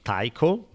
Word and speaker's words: Tycho: How the hell do you pronounce T-Y-C-H-O? That's his first name. – Tycho. – Tycho? – Mm-hmm. – Tycho, okay. Last name, B-R-A-H-E.